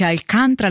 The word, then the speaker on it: Alcântara: E questa era la voce della cantante portoghese Carminho Marcha de Alcântara, così si pronuncia Alcaranta, che io l'avrei detto così, invece Alcântara l'abbiamo sentito cantato da Carminho, che ci ha cantato una marcia popolare che fa palpitare il mio cuore, un brano tratto da Portuguesa, un album del 2023.